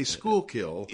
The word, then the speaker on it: Schuylkill: You know, but until they get here and people tell them how to say the proper way to say Schuylkill, which is Schuylkill.